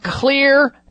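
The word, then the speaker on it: Xlear: Well, you get into the store, and I'll tell you which stores to go to get a hold of Clear Nasal Spray. That's spelled with an X for xylitol, which is one of the main ingredients in Clear Nasal Sprays.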